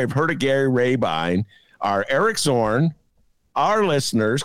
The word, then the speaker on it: Rabine: That's how you pronounce the name, people. Gary Rabine.